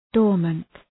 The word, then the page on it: dormant: ‘dɔ:rmənt